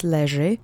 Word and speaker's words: leży: Okay, let's say that together three times, leży. The accent is on the first syllable.